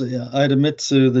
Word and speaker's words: Idemitsu: but Idemitsu, a Japanese group. – Yes, Idemitsu, the Japanese conglomerate, upped its stake in Delta Lithium up to 15% here.